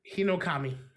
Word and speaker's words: Hinokami: Hinokami.